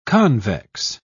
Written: convex

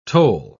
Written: tole

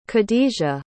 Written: khadijah

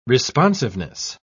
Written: responsiveness

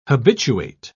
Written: habituate

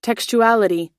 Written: textuality

